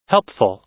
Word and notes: helpful: American English

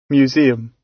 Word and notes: museum: British English

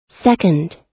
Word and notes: second: American English

